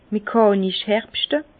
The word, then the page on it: mechànisch herbschte: Bas-Rhin; Herrlisheim